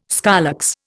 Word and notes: scolex: Indian

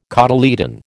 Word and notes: cotyledon: American English

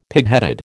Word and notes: pigheaded: American English

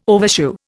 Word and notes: overshoe: Indian

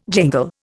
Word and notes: jingle: Indian

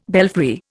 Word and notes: belfry: Indian

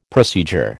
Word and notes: procedure: American English

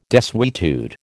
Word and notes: desuetude: American English

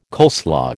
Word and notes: coleslaw: American English

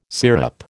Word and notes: sirup: American English

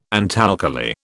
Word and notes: antalkali: British English

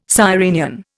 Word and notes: sirenian: Indian

